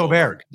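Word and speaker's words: Åberg: We know, okay, Ludvig, how are we pronouncing this? Because I've heard like 7 different pronunciations. Oberg, Aberg, Iberg. What is it? I mean, where are we at with this guy? – If Oberg? I try really hard, it's Oberg, but I always say it's Oberg. Yep, Oberg. Oberg.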